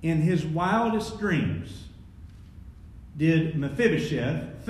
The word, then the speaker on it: Mephibosheth: The Hebrew name is actually pronounced Mephibosheth, meaning from the mouth of God Bashtu.